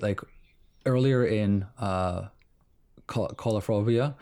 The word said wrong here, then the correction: Coralophobia: Every time. – Oh, hang on. I'm going to listen to your pronunciation. Coulophobia. Okay. – Coralophobia. Coralophobia. I have a fear of coral.